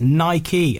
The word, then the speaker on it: Nike: Does Nike rhyme with like? Apparently not. Chairman Philip Knight confirmed the pronunciation in 2014 was, in fact, Nike, after the Greek goddess of victory.